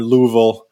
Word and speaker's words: Louisville: It's got you got to have like you got to say it, yeah, you got to say it right. – You got to say Louisville.